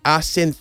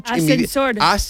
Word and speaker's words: ascensor: Bueno, yo creo que pues, César y Susana subieron 6 pisos en el ascensor azul sin saber que estaba averiado. Entonces, la palabra siempre lo digo mal. Yo no, ascensor. – Es que yo salto la C. Ascensor.